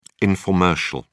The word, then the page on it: informercial: British English